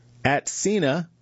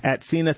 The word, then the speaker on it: Atsena: At Cena sees early promise in treating patients with rare eye disease, and that's spelled A-T-S-E-N-A. At Cena